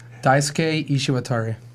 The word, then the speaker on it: Daisuke Ishiwatari: Daisuke Ishiwatari.